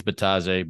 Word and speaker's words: Bitadze: I think the D is silent on his last name, so I think it's Bitadze, but most people are just going to call him Goga anyway.